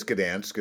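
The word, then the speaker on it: Gdańsk: I think it's Gdansk is how you pronounce it.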